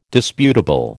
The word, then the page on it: disputable: American English